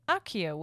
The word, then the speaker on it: Accio: So and then second thing, Accio is Latin and pronounced Accio with like a KK sound, so thank you for letting us know.